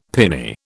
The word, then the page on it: pinny: British English